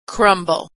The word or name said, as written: crumble